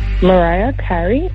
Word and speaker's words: Mariah Carey: Mary Carey, Always Will Be My Baby. – Say the artist again. – Mariah Carey. – There we go. Yes. Okay. Perfect. I got to make sure you say it correctly.